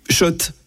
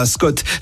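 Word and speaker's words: Schott: Oui, Shot et pas Scott. Ça fait des années qu'on prononce mal la marque.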